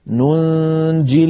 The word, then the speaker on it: ننجي: ننجي أصلها ننجي مضارع بوزن نفعل، فلام الكلمة ياء ننجي، لم تكتب هذه الياء في المصحف لأنها ستسقط في اللفظ عندما نصلها مع كلمة المؤمنين، فيكون النطق ننجي المؤمنين وصلا. أما إن وقفنا فنقف كالرسم ننج من غير ياء في آخرها.